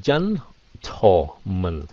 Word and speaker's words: gentleman: gentleman yes đọc là gentleman so cái âm thò là cái âm này đi ha cho dễ đừng có chân tổ không phải là tổ không phải là tàu